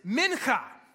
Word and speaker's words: mincha: The word is mincha. Mincha, M-I-N-C-H-A, mincha.